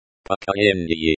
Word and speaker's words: Поколение: Поколение. Поколение, the stress is on the second syllable, ко.